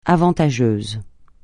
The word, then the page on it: avantageux: francais